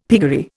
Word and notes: piggery: Indian

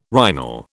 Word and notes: rhinal: British English

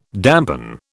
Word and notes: dampen: British English

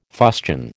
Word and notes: fustian: American English

